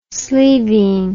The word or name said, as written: sleeveen